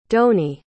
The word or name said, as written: donie